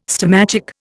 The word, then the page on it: stomachic: Indian